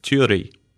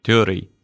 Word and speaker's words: turi: It's turi. – Turi. – Turi. T-U-R-I. Turi. – Turi. Turi. – Yeah, you're going to have to roll your R's on that one.